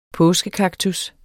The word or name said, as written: påskekaktus